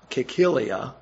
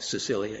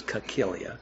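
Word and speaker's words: Cilicia: including both Cyrenians and Alexandrians and some from Cicilia, that's how it's pronounced, and Asia. Asia, you may think Sicilia, it's Cicilia, and Asia, rose up and argued with Stephen.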